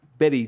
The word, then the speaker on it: Bette: She was named after Betty Davis, although Davis pronounced her first name in two syllables, and Midler just goes by Bet.